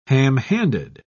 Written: ham-handed

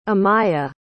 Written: amya

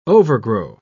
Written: overgrow

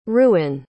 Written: reuhen

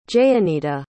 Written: jayeenita

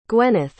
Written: gweneth

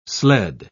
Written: sled

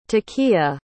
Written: takiyah

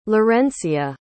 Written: laurencia

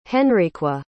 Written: Henriqua

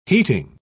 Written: heating

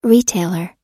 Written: retailer

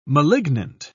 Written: malignant

